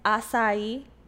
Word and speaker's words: acai: No, it's acai. It's acai. – Acai berry. – I went to Starbucks one time and I asked for an acai refresher. And the girl's like, it's acai.